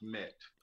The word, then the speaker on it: MMET: It's actually pronounced MET. – Oh, sorry. – Oh, no problem. No problem. Pronounced MMET, MET.